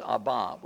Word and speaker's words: Abba: The correct pronunciation of that word is Abba. We cry Abba. I don't care if you say Abba or Abba.